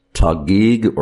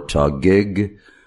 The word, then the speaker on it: Taguig: And he clarified that he is from, and I'm probably going to mispronounce this, Taguig or Tagig. Or Taguig City, T-A-G-U-I-G, which is in Metro Manila.